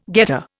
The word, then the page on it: getter: Indian